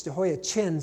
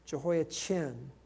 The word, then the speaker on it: Jehoiachin: I said his name wrong. It's Jehoiachin. Think of Chin. I was thinking of Kim, and there's another Kim, Jehoiakim. A king was released from prison, verse 31, Jehoiachin.